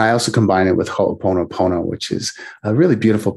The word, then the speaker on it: Ho'oponopono: Ho'oponopono. I'll have to spell it for you. Yeah, it's H-O apostrophe O-P-O-N-O P-O-N-O.